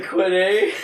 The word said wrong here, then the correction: quinoa: Quinoa, there we go. It takes a mistake to remember it.